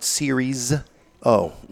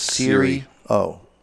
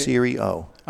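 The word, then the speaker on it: Serie O: And I don't like when people add the S. It is not Siri's O. – Mm-hmm. – It is Siri O. Siri like your phone. Yeah. Siri O.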